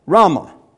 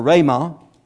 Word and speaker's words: rhema: That word there is a Greek word called rhema or rhema. I don't know how to pronounce Greek, so I'll pronounce it my way. Okay. R-H-E-M-A.